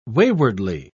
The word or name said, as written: waywardly